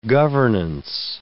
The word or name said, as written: governance